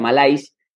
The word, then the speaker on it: malaise: se escribe malaise en inglés, la palabra malaise, pero se pronuncia malaise, significa angustia, enfermedad y malestar.